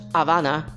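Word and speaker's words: Habana: Havana Syndrome, by the way. You don't even pronounce the H when it's Spanish, but whatever.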